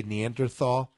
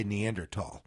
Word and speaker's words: Neanderthal: And by the way, I'm not saying the TH. In America, we say Neanderthal. In Europe, in Australia, they say Neanderthal.